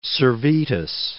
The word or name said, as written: Servetus